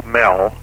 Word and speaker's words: Moell: The Internet address is just my name, Joe Mell, as one word, J-O-E, and it's spelled M-O-E-L-L.